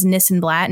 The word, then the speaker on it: Nissenblatt: My personal, you can find at Ari This and That, because my last name is Nissenblatt and it rhymes with this and that.